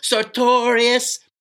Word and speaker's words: sartorius: Like we used to say in dissection when I was going to school, sartorius. You remember Duran Duran with the song Notorious? We used to say it like that with the sartorius muscle and grab the muscle and go, sartorius.